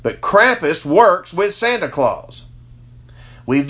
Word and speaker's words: Krampus: Who is Krampus or Krampus? However you want. It's K-R-A-M-P-U-S. I pronounce it Krampus.